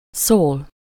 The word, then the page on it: szól: /soːl/